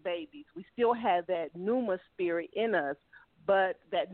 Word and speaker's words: pneuma: Pneuma. It starts with a P. P-N-E-U-M-A. The P is silent, as in like pneumonia. The P is silent, but it's spelled P as in Paul, N-U-E-M-A, pneuma.